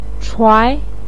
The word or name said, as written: chuai2